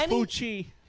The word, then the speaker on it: Fauci: It's Fauci.